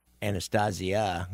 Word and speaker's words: Anastasia: Everybody's seen that image of Albert Anastasio's Anastasia. Now, I've said his name right, or the original way it was spelled.